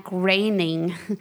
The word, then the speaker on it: Groening: Yeah, I got to talk to Matt Groening.